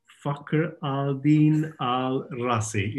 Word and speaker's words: Fakhr al-Din al-Razi: and the Hidden Secret of Fakhr al-Din al-Razi, if I pronounce that correctly. – That's right.